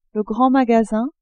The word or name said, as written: grand magasin